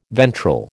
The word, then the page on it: ventral: American English